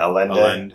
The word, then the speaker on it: Allende: A-L-L-E-N-D-E. – Yeah, Alenda. Alenda, I think they say that's how it's pronounced.